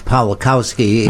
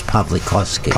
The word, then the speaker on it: Pawlikowski: If you anglicize it, it's Pawlikowski. If you give it the real Polish pronunciation, it's Pawlikowski.